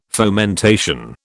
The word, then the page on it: fomentation: British English